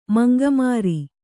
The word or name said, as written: ಮಂಗಮಾರಿ